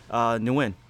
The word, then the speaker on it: Nguyen: I've had people come up to me and correct the way I pronounce my last name. – Because you don't know your last name. – Apparently. They would say, what's your name? Nguyen. You mean win? – No.